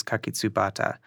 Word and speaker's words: kakitsubata: And the word iris in Japanese, ancient Japanese, is kakitsubata.